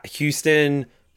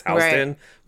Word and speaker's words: Houston: If Charlene is selling plus-size clothing at the Houston Pressed spot, I almost said Houston because that's how New York City pronounces their subway stop is Houston, but it's Houston, Texas.